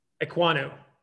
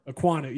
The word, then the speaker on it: Ekwonu: Ekwonu. – Okay. – Ekwonu. – Ekwonu.